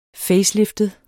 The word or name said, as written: faceliftet